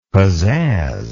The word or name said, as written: pazazz